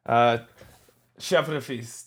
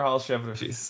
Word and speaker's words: Hall-Chevrefils: Hall-Chevrefils. Tyler Hall-Chevrefils.